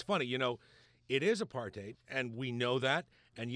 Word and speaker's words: apartheid: And the one of the things that she learned there was it's not pronounced apartheid. It's pronounced apartheid. – Indeed. – And one of the things about that is it's easy to remember because there's a hate in apartheid.